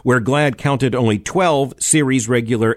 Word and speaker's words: GLAAD: The name of the organization is pronounced GLAAD, but it's spelled G-L-A-A-D.